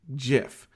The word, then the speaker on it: GIF: So get graphics is with a hard G, okay? And also, you don't give someone a GIF.